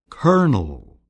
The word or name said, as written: colonel